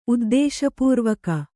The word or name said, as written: ಉದ್ದೇಶಪೂರ್ವಕ